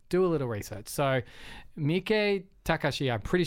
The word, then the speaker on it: Miike Takashi: So it's like Miike Takashi is how you pronounce it,